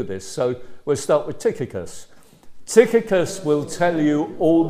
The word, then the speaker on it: Tychicus: So I'm told that the way you pronounce these words that we're going to come across is Tychicus, okay? Generally speaking, if you get CH, like in Christ, it's ch rather than ch.